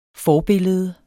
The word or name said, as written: forbillede